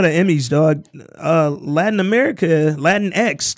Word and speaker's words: Latinx: But when it's a lowercase x, you got to pronounce it Latinx.